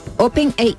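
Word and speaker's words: OpenAI: Open AI.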